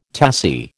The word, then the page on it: tassie: American English